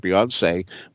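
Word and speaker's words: Beyoncé: But once I heard Beyonce, which is meant to rhyme with fiance, it certainly made me realize, we're in the here and now.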